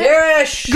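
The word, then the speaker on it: Varys: Oh, Varish? Is that how you say it? – Varish! – Girl, yeah. – Vanish! – Vanish, Varish, thank you.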